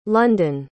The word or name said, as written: london